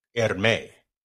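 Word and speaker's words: Hermès: Um, outside of and the- and, and and apparently the uh the, the H is silent. Hermes.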